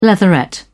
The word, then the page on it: leatherette: British English